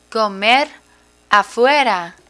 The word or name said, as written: comer afuera